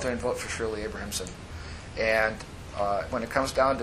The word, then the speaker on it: Abrahamson: Shirley Abramson. Abrahamson.